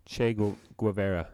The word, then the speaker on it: Che Guevara: Che Guevara.